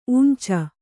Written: ಊಂಚು

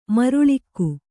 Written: ಮರುಳಿಕ್ಕು

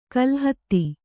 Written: ಕಲ್ಹತ್ತಿ

